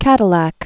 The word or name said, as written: Cadillac